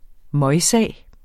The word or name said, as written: møgsag